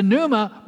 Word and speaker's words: pneuma: In English, we write it as P-N-E-U-M-A. It's, in Greek, it's pronounced pneuma.